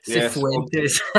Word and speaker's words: Cifuentes: But in terms of how do you say, is it Cifuentes? – Yeah, Cifuentes. Cifuentes. There we go.